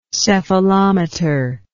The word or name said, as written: cephalometer